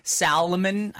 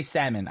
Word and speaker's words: salmon: Should we start saying Salomon? I say salmon. I don't pronounce it. – You do have those people out there that say Salomon, though. – Oh my God. I hate the people with the L in the middle.